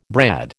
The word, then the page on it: brad: American English